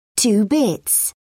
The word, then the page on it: two bits: British English